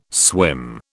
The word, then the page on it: swim: British English